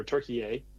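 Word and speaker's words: Türkiye: and just learn a ton about Turkey, or Turkey, as the proper pronunciation.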